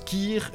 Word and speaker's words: cher: Sauf qu'en Picard, le mot « cher » se prononce « kir » et le mot « boucher » se dit « bouchir ».